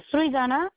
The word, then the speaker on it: Srijana: Srijana, S-R-I-J-A-N-A. – What is it? – Srijana. – How do you spell it? – S-R-I-J-A-N-A.